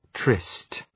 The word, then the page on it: tryst: trıst